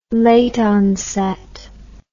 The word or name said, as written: late onset